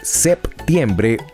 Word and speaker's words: septiembre: Ambas formas son correctas, pues tanto septiembre con P como septiembre sin P aparecen registradas en el Diccionario de Uso del Español de América y España. Esto se da porque muchas personas no pronuncian la P, entonces la Real Academia determinó permitir ambas posibilidades.